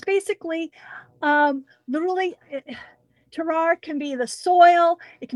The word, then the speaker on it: terroir: Is it terroir? What? Say it again. – Terroir? – Terroir.